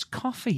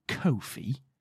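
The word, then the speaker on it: Ko-fi: We have a coffee at the Stardom Cast. Again, no. – You say Kofi wrong and it annoys me. – It's coffee. – Kofi. – It's coffee. It's not Kofi. – It's K-O-F-I, Kofi.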